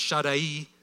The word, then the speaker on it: Shaddai: It's actually pronounced Shaddai, but everybody says Shaddai, and I'll probably continue to do that.